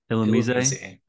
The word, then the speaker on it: Illumise: Illumise.